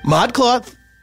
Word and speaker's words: ModCloth: ModCloth.